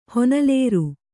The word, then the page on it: ಹೊನಲೇರು: honalēru